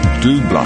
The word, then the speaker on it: Double: Here's a song I haven't heard for quite a while, The Captain of Her Heart, by, well, it looks like Double, spelt Double, but I'm pretty sure it's a French pronunciation, so it's Double, and The Captain of Her Heart.